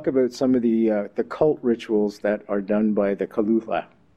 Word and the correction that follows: Cthulhu: I think I've mispronounced that. How do you pronounce that? – I'm pronouncing it Cthulhu. – Cthulhu.